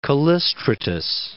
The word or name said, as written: Callistratus